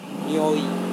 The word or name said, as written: nioi